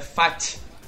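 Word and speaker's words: Pfaadt: É, é, é Fat. É o, é, o P é mudo e o D é mudo. É Fat. É Fat, tipo de, de fat, de gordo, mas fala Fat.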